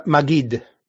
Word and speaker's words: Maggid: And we're going to get into, is it pronounced Maggid? – Maggid.